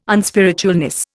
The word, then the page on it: unspiritualness: Indian